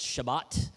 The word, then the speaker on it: Shabbat: That word Shabbat, we pronounce that in English as Sabbath.